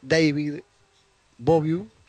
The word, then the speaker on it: David Bowie: Así me lo dice Fernando Tebele, que hay que pronunciarlo. David Bowie, y que hace pocas horas también falleció.